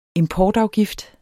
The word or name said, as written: importafgift